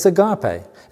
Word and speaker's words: agape: Well, it's the Greek word agape, or agape, or whichever way you want to pronounce it. It's agape.